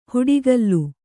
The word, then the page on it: ಹುಡಿಗಲ್ಲು: huḍigallu